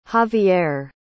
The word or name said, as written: javier